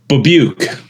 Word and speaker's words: Babiuk: Babiuk.